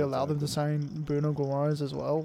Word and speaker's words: Guimarães: I think Eddie Howe has a vision of how he wants to play, and I think that Bruno Guimarães, or however you say his name, Guimarães.